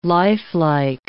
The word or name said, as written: lifelike